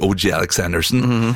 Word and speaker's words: O.G. Alexandersen: Eller skal uttales på engelsk, O.G. Alexandersen, er en genistrek.